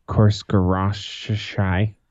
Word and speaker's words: Khosrowshahi: On today's conference call with investor CEO Dana Khosrowshahi said, perfect pronunciation.